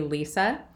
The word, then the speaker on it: Lise: So first of all, you're Lisa, but you spell it L-I-S-E. – This is true. Yep, I'm a Lisa with an E,